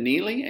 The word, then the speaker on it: Nealy: My name is Brandon Neely, and you can connect with me on Facebook. My last name is spelled N-E-A-L-Y.